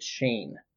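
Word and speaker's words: Schoen: I found this out back a few months ago, but it's spelled Shoen, but you say it Shane.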